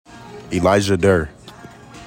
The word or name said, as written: ELIJAH DURR